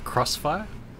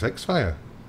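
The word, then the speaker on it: Xfire: pronounce it Crossfire? I always said X-Fire. – Except, I think it's X-Fire. I'm okay. sure it is. Anyway. – I heard it both ways.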